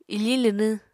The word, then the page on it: ifiñ linwñ: apatani